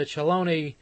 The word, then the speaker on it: Cheloni: And then Eddie South and his international orchestra on a radio transcription made for the Celoni Company, actually, but it's spelled C-H-E-L-O-N-I.